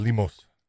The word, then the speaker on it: Lemos: But I thought, how do you say her name? Lemos? – Amanda Lemos.